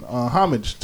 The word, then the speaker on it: homage: I think the H is silent, but go ahead. – Homage? – Isn't that a French word? – Like homage?